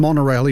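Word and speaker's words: Monaurail: Osaka Monorail. I think you do say it as monorail, even though it's not spelt that way. M-O-N-A-U-R-A-I-L. I'm pretty sure it's pronounced that way.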